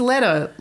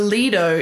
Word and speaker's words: Leto: It's Leto, like in the ghetto. No. Uh huh. As opposed to Lido in the Guido.